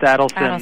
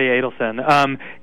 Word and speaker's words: Adelson: So, Adelson, and I think it's actually pronounced Adelson, okay. – although many people say Adelson, including some people that know him.